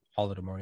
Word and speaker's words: Holodomor: And by the way, similar things happened in, I think I'm pronouncing it right, the Ukraine, the Holodomor? – Holodomor, yeah.